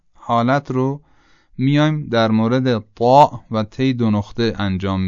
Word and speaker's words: ط: تی دو نقطه خب تی معمولی است که در فارسی به کار میبریم، حالا همین تی دو نقطه رو بیایم غلیظش بکنیم میشه طا. یعنی تلفظ از همون جایی صورت میگیره که تی دو نقطه صورت میگیره، با این تفاوت که طا درشته اما تی دو نقطه این درشتی رو نداره.